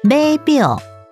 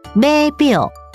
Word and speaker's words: 码表: 码表是一种运动或比赛的计时器，码表台语念作贝表，贝表，客语念作码表，码表。